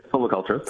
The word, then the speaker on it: silviculture: How do I say it? – That's right, silviculture.